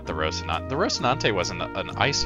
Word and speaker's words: Rocinante: Rocinante.